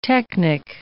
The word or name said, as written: technic